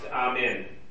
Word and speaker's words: amen: You would finish it by saying, now in Hebrew it would be pronounced amen. Amen. No emphasis on either syllable. Amen. That's how you would say it in Hebrew. Not that it matters. You can say it amen. You can say it amen. It doesn't really matter.